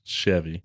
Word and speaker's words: Chevy: Did you say Chevy? – Chevy. – It's Chevy.